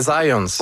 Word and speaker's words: Zajonc: Non, c'est Zajonc. – Oui? Okay. – Ouais, j'ai-tu pas dit Zajonc? – Ben, vous avez dit plus Zajonc, mais... – J'ai prononcé un petit peu à l'anglais. J'ai prononcé mon polonais à l'anglais.